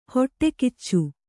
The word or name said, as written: ಹೊಟ್ಟೆಗಿಚ್ಚು